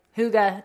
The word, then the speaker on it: Hygge: And I was like, Hygge, it means cozy in Danish. He's like, uh, okay. Yeah, try and Google Hygge and you will be wrong.